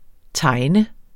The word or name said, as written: tejne